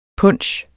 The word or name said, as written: punche